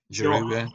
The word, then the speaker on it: Giroud: Yeah, Giroud.